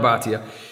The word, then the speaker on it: Bhatia: And I know, like, we know John, so we know John, is it Bhatia? Am I saying it right? Yeah, John Bhatia.